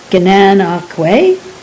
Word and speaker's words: Gananoque: I did, though, after bringing a smile to the attendant's face with my awkward pronunciation, learn the two correct ways to pronounce Gananoque. Gananoque or Gananoque.